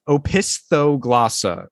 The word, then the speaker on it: opisthoglossa: So again, it's pronounced opisthoglossa. Opisthoglossa.